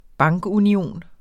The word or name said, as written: bankunion